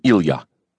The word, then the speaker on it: Ilya: When friends called him Ilya, it was with the proper Russian pronunciation, with the accent on the ya.